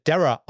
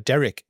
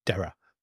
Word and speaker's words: Darragh: Nur als kleiner Tipp, der wird Dara ausgesprochen. Der Kommentator bei ran.de, der das gemacht hat, hat ihn immer Derek genannt. Nee, Dara wird das. Ist sein Name D-A-R-R-A-G-H geschrieben.